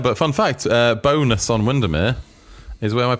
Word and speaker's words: Bowness: The definitive answer to the question, how do you say Bowness Baroness is in? and the real answer is we're all wrong. Oh, Baroness, B- bonus. – Bonus bonus, is what the locals say.